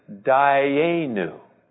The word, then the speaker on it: Dayenu: The word is Dayenu. Dayenu. I'll write it phonetically here. Dayenu.